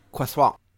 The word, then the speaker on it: croissant: How do you feel about croissant? – I am dying inside.